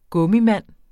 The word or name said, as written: gummimand